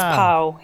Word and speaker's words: Pau: I was like, oh, it's Paulina. That's how you pronounce it. – It's Pau. That's why a lot of people call me Pau. Like, I'm known as Pau.